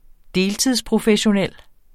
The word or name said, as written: deltidsprofessionel